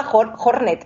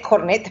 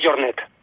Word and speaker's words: Jornet: Yo le llamaba Jornet en vez de Jornet. Pero, realmente la pronunciación es Jornet.